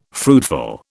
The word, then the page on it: fruitful: British English